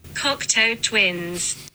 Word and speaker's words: Cocteau Twins: Cocteau Twins. Eh, ecco, eh, avete... – Cocteau Twins.